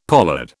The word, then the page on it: pollard: British English